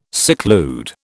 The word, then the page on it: seclude: British English